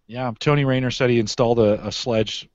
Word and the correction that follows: Schlage: Is that how you pronounce that? No, Schlage. Schlage, right?